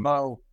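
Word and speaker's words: Beau: And some of their better defensive players are guys K-State recruited hard, and junior safety Bu Freiler. I might have mispronounced his name. – Bo.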